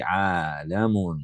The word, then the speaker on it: عَالَم: عَالَمٌ. This one we must make sure to pronounce it properly. وَكُلُّ مَا سِوَى اللَّهِ عَالَمٌ. We pronounce the lam here with a fatha.